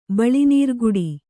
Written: ಬಳಿನೀರ್ಗುಡಿ

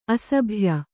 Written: ಅಸಭ್ಯ

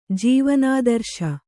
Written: ಜೀವನಾದರ್ಶ

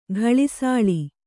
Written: ಗಳಿಸಾಳಿ

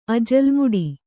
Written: ಅಜಲ್ ಮುಡಿ

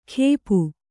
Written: ಖೇಪು

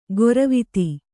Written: ಗೊರವಿತಿ

